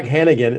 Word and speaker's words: Handegan: Yeah, you know, Jack Handegan.